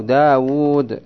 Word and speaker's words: داود: وكالواو التي يلفظ بها بين الواو والدال في داود، داود دا حركة بعدها ألف سكون، بعدها واو مضمومة حركة، بعدها واو تنطق ولا تكتب سكون. نعم. وكالألف التي يلفظ بها ها تحسب واوان. نعم. كم تكتبها أنت الرسم من حيث الرسم؟ واو واحدة لكن من حيث النطق، وتنطق واوين، واو متحركة بحركة وواو ساكنة بسكون.